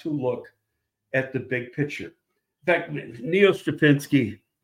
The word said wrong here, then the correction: Stopchinski: am I saying his name right? Uh, Stopchinski. Stop.